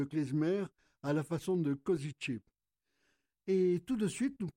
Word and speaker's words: Košice: La prononciation de notre ville, c'est Košice.